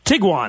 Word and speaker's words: Tiguan: I think it's spelled T-I-G-U-A-N. – Tiguan.